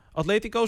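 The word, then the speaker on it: Atlético: Atletico, zeg ik dat goed? – Heel goed. Atleti.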